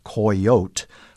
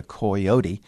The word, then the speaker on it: coyote: And the Spanish version of that was three syllables. They took the Indian word coyote and turned it into coyote, coyote. And when Anglo-Americans heard it, especially the mountain men who were in the southern and central Rocky Mountains in the 1840s and 1850s, I think it sounded a little too fancy for them. And so they abbreviated it to two syllables, to coyote, and then took that back to the Midwest, the South, to Kentucky and Virginia, where many of them had come from.